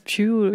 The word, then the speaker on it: Pugh: aber halt auch vor allem Florence Florence Puff? Florence Pugh. Ich würde sie Pugh aussprechen.